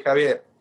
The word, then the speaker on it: Javier: You know, in the U.S., my name will be Xavier. That's how it's spelled. But in Spanish, we say Javier, and at least where I am from. There's other ways to pronounce it.